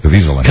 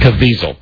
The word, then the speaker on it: Caviezel: Caviezel? – Caviezel. – Caviezel. Mm-hmm. Thank you. What daredevil dresses up like Jesus to perform death-defying motorcycle stunts? – It's so funny. – Evil Caviezel.